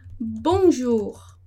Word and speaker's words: bonjour: Então, ali vocês têm o O e o U e vão falar bonjour, bonjour. E aquele R no final que vocês vão pronunciar, é bom se habituar, repetir, vai na frente do espelho, fica lá, né, treinando esse R, porque a nossa boca, ela tem que ser treinada para essa língua, né?